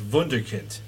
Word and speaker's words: Wunderkind: It's Wunderkind. If you're pronouncing it the German way, it's got the V instead of the W.